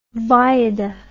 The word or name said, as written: Wajda